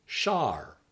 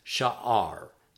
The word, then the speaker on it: sha'ar: Word 56, gate, is pronounced shar, shar, shar. And like a couple of words we've seen earlier, there's two possible pronunciations. This word technically has two syllables, and so if you wanted to emphasize that, you could pronounce it as sha'ar, sha'ar, sha'ar.